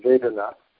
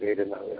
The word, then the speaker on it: vedana: Distress, this vedana, the long A at the end, vedana, actually, the word vedana means pain, torture, agony, and so on.